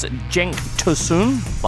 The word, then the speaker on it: Cenk Tosun: Also coming up today, how do you pronounce Cenk Tosun? Like that.